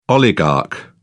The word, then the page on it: oligarch: British English